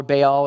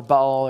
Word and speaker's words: Baal: So you're going to hear me say Baal or Baal or Baal or pronounce it whichever way. There's a B involved and there's an all involved. As long as you got that, you probably pronounced it right.